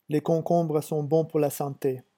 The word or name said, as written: les concombres